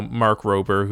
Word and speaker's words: Mark Rober: like I know Mark Rober, who is another YouTuber. Did I pronounce his name wrong? – No. That's how I say it.